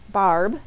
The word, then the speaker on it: Barb: So if you take a moment and you email me, Barb, B-A-R-B,